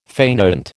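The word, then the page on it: fainéant: American English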